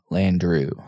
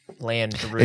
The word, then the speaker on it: Landru: Landru. – Oh, Landru. – It's like Andrew, but with an L. – And a U. – U instead of an EW.